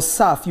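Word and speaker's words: caph: The door there means, is the word kaf or saf. You pronounce it, it's C-A-P-H, but it's, you pronounce it S-A-F, saf.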